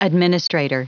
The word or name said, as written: administrator